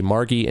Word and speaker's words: Margie: This was submitted to us for review by Margie, I believe. I think the G is a hard G. Margie and Jeff, who run Wales-Online.